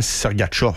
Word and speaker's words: Sergachev: Parce qu'il fallait dire aussi Mihal Sergachev. – Exact. – Mais tout le monde disait Sergachev. – Ouais, même principe avec le gardien des Rangers.